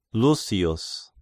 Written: Lusius

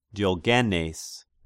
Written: Diogenes

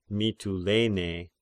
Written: Mitylene

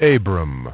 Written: Abram

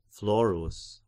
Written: Florus

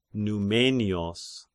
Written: Numenios

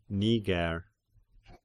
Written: Niger

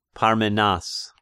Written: Parmenas

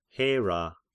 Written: Hera

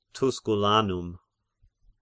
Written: Tusculanum

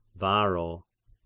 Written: Varro

